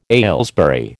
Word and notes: Aylesbury: American English

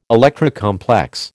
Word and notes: Electra complex: American English

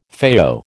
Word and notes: FAO: American English

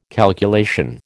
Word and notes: calculation: American English

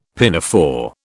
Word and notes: pinafore: British English

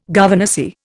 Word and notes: governessy: Indian

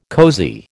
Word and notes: cosy: American English

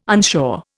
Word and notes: unsure: Indian

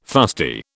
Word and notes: fusty: British English

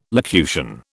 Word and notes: locution: British English

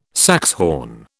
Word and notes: saxhorn: British English

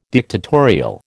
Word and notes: dictatorial: American English

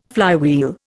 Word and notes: flywheel: Indian

